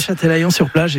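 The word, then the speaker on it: Châtelaillon-Plage: Alors, vous n'avez pas y resté très longtemps non plus, vous revenez en France et vous allez, je ne voudrais pas l'écorcher, à Châtelaillon-sur-Plage, c'est comme ça qu'on le prononce. – Oui, Châtelaillon-sur-Plage, exactement, à côté de La Rochelle.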